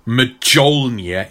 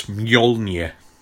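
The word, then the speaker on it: Mjölnir: To confront Not many hard J's. It's not like Mjolnir, it's Mjolnir.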